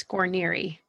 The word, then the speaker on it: Guarniere: It's Guarniere.